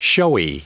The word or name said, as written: showy